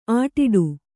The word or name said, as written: ಆಟಿಡು